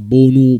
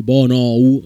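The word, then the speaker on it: Bounou: Eh, sì, è un, quasi un'omonimia perché in realtà si chiama Bonu, Bonou, si scrive la, mm, il suo nome.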